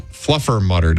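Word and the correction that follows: Fluffernutter: Day. – Nope. Try again. – Not for you? – You're still saying it wrong. – Oh, Fluffer Nutter Day.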